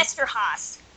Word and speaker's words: Eszterhas: and written by Joe Estevez. Is that how you pronounce his name? Eszterhas. – Haas. – Yes.